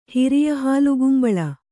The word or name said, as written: ಹಿರಿಯಹಾಲುಗುಂಬಳ